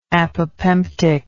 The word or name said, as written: apopemptic